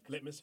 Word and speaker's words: litmus: And I think the impetus was like to put the words inside a body and the body to be some sort of like lachmus test. Is that how you say it in English? Like litmus test? – Litmus test.